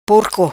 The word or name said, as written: Porco